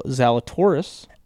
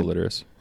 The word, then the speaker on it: Zalatoris: Will Zalatoris. Like, that's the right way to say it. – Zalatoris.